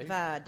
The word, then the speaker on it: Vidalia: Pronounced the proper way. – Vidalia.